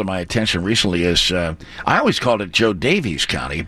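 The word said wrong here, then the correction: Jo Daviess: but it's I guess Joe Davis County is the correct pronunciation.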